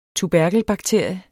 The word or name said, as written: tuberkelbakterie